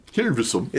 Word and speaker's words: Hilversum: Hilversum.